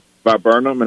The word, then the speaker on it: viburnum: I bought a viburnum, and I hope I'm saying that correctly.